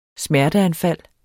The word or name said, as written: smerteanfald